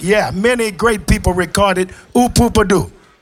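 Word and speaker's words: Ooh Poo Pah Doo: Cette chanson s'appelait Hoopa, je la prononce très mal, hein. – Hoopadoo. – Hoopadoo.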